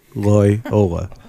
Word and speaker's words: Loyola: That's how you say it. Loyola. Loyola. There we go.